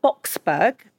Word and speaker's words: Boksburg: You were born in Boksburg, am I pronouncing that right? – That's right.